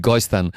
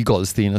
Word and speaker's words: Goldstein: Gil Goldstein, che dire di Gil Goldstein, ha raggiunto una notevole, che si può dire anche Gil Goldstein a secondo se lo si pronuncia alla tedesca o all'americana, lui preferisce Goldstein, raggiunge una notevole fama sia come strumentista alternandosi tra pianoforte e fisarmonica, che come compositore e arrangiatore.